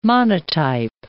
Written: monotype

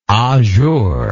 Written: ajour

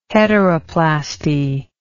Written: heteroplasty

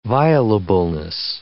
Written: violableness

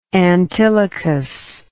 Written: antilochus

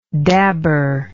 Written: dabber

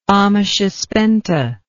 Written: amesha spenta

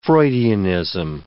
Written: Freudianism

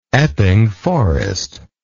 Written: epping forest

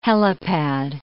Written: helipad

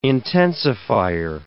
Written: intensifier

